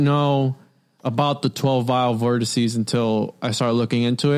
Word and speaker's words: vortices: We're going to be doing Strange Wands, the Vile Vortices. – Vortices. I was saying vortexes, and I was like, that's not right.